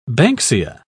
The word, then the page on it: banksia: British English